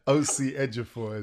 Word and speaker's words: Osi Ejiofor: So I'm really gracious that I have the time of Brother Usi Ejiofor. Have I said that right, sir? – Kind of. OC Ejiofor is fine.